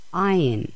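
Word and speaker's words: Ayin (עין): Ayin, this is a silent letter.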